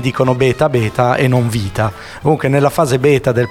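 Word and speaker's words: vita (βήτα): Adesso bello, diciamo che è una fase che io chiamo vita, perché in greco beta, noi lo diciamo beta, ma in realtà si pronuncia vita, mm-hmm. eh, perché la B in greco si pronuncia V, ma i greci sono...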